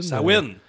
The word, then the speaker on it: Samhain: Sawin! ça s'écrit S-A-M-H-A-I-N, mais on dit Sawin.